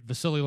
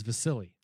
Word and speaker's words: Vasily: This is a little confusing for me because December 9th on ESPN, you're going to get Vasily Lomachenko. And yes, it is Vasily. – Yes, Vasily.